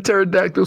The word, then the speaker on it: pterodactyl: Marcos, Definitely started that pterodactyl search with a T. – I mean, who has a silent P?